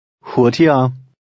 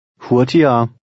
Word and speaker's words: hurtigere: Remember the sound of the G in the middle of a word. – Hurtigere. Hurtigere.